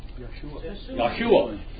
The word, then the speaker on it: Yeshua: By the way, what's the correct pronunciation of Jesus? Not really Jesus, but what? From the Hebrew? – Yeshua.